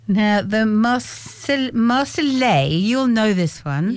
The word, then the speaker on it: Marseillaise: The Marseillaise. – Marseillaise. – Yeah. You don't say the L's are silent. – No, it's a yes sound.